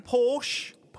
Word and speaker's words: Porsche: Hang on, say that again. – Porsche. – Porsche. Porsche. I don't like Porsche. – That is the correct pronunciation of the automobile, isn't it? – Luke doesn't like Porsche.